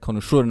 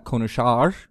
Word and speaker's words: konuşur: Right, konuşur, not konuşar. Konuşur.